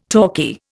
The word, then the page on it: talkie: Indian